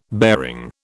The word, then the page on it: bearing: British English